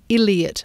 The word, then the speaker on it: Iliott: All right, now back in Australia and a song from Penny Davies and Roger Illiott. Illiott, I-L-I-O-T-T. It's not just my bad pronunciation.